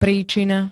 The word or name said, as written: príčina